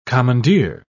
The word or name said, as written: commandeer